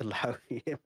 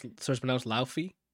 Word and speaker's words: Laufey: Yeah, I guess you could say I love to Laufey. – So it's pronounced Laufey. – Either Laufey or Lauf. It's Loki's mom in God of War. The way that that's pronounced.